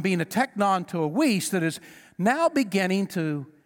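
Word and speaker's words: weis (υἱός): But then there is another word that's pronounced weis. It's spelled H-O-I-S-E, but it's pronounced weis, and it is...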